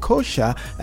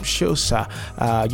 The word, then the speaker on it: Xhosa: and that's the Xhosa culture. And some pronounce it Xhosa and some Xhosa, you know, a South African tribe or civilization.